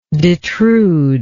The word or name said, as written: detrude